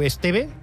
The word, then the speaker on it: Esteve: És una cançó que vam sentir ahir, interpretada pel cantant Edu Esteve. – Esteve. – És que si ja s'obre, no ho pronuncies bé.